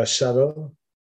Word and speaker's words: subtle: I think I've been very subtle. I've been so subtle, I don't even pronounce the B in the word subtle.